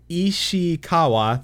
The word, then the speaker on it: Ishikawa: There's gonna be the Yoshi P and Natsuko Ishiwa uh, Ishikewa. All right, I'm butchering his last name. Natsuko Ishikawa. There we go.